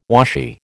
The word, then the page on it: washy: American English